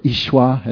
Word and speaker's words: Yeshua: And if Yeshua through you, or Yeshua, or Ishwa, as I just heard it should be pronounced, that's the Aramaic name of Jesus.